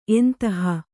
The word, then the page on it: ಎಂತಹ: entaha